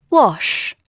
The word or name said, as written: wash